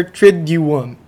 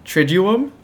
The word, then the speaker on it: Triduum: Today we're going to talk about the Holy Week, specifically on the Easter Triduum. – Triduum? – Triduum.